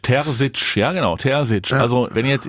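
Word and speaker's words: Terzić: Ich habe gestern schon wieder die wüstesten Ausspracheproduktionen gehört von Terzic. Nein, Terzic. Ja, Edin Terzic. Genau, 38 Jahre jung. – Mit einem weichen S.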